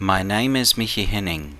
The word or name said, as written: Michi Henning